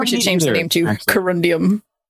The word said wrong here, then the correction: corundum: I would love some corundum. I said it wrong the first time.